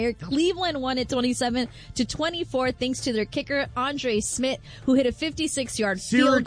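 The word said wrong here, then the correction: Szmyt: Syracuse legend, Andre Schmidt. – Oh, my bad. – Legend. – Is it Schmidt? – Schmidt, yes. It's spelled S-Z-Y-M-Y. Yes.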